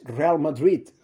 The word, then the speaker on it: Real Madrid: Igual los, los de habla inglesa lo, llaman así, porque no. Pues no, es Real Madrid. Los que no saben eh, pronunciar bien el nombre.